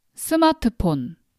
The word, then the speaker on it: 스마트폰: So how do Koreans pronounce smartphone? We call it as 스마트폰.